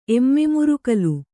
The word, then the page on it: ಎಮ್ಮೆಮುರುಕಲು: Kannada